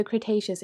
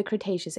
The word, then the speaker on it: Cretaceous: Okay, Wiki tells me I pronounced it correctly. So the Cretaceous era. So the Cretaceous era, that is when they date back from.